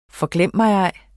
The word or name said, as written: forglemmigej